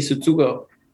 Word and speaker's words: Tsutsugo: Tsutsugo? – Yoshi Tsutsugo.